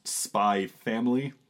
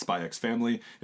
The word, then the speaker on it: Spy x Family: And the current king of Jump Plus is a little-known title known as Spy X Family, which for the record, is pronounced Spy Family, not Spy X Family. It's the whole Hunter Hunter thing all over again.